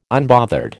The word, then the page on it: unbothered: American English